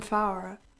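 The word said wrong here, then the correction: Fauré: From Foray. – Foray, sorry. My pronunciation is terrible.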